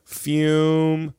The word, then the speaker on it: Fume: Real quick, let's talk about one of our amazing sponsors over here at Legion of Skanks, and that of course is Fume. Yes, it's spelled F-U-M, but pronounced F-U-M-E. Fume, everybody.